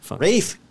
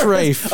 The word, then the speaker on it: Ralph: Rafe. – Rafe. We talked about this before. – It's spelled Ralph, but it's pronounced Rafe.